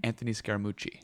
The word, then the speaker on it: Anthony Scaramucci: Anthony Scaramucci.